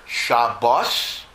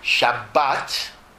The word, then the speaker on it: Shabbat: But technically, you have to pronounce it either Shabbos, if you follow the almost extinct Ashkenazic pronunciation of Hebrew, but it's almost extinct, or Shabbat, and Torah also is Torah, not Torah, because in Hebrew, most words are accented in the last syllable, not the first syllable.